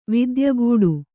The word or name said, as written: ವಿದ್ಯಗೂಡು